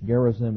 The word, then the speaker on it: Gerizim: They offered sacrifices on Mount Gerizim, which Gerizim is G-E-R-I-Z-I-M. Sometimes people will pronounce it Gerizim.